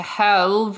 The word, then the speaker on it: halve: When an attacker that you can see hits you with an attack, you can use your reaction to halve the attack's damage against you. – That's pretty nice. – Is halve spelled like salve? – Yeah. – Okay. – Yeah, it's also pronounced like salve.